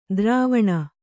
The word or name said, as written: ದ್ರುವಣಿ